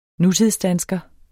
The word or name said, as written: nutidsdansker